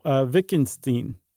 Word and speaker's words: Wittgenstein: It kind of gets to what Wittgenstein, is that how you would say it in German? – Yeah.